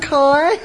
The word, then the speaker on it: Kai: Gerda and Kai. – Som I ved fra beretningen om Gerda og Kai. – Kai gets some of the glass in his eye. – Så får Kai noget af det her glas i øjet.